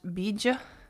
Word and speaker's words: Bija: And it's actually called a Bija mantra, which Bij is, I believe, just B I J, but it's pronounced Bija and so it's often spelled B I J A.